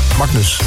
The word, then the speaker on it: Magnus: Magnus. Zeg ik dat goed, Martijn? – Ja. – Magnus, oké.